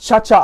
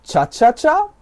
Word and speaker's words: Chacha: Und wer im Internet noch diskutiert wird, ob das jetzt Chacha ausgesprochen wird oder Chaka oder was das ist, ja, es geht darum um Tänze, ja, Chacha, ja.